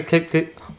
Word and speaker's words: Kapky: Everyone, listen up, this is how you pronounce it. Kapky, kapky, wait. – Kapky. – Kapky. – Proti. – Proti. – Yeah, but you're not a cat. – Proti. – Proti. – Proti. – Slzám. – Slzám.